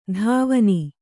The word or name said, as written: ಧಾವನಿ